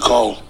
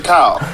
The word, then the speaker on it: Kyle: You said Cole. – I said Kyle.